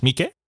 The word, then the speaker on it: Miike: Yes, Miike.